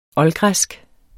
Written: oldgræsk

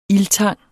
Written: ildtang

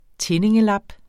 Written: tindingelap

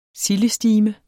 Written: sildestime

